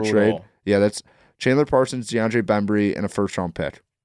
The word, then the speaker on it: DeAndre Bembry: I was going to do DeAndre Bembry.